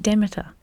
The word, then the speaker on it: Demeter: Yes, that's right, Demeter.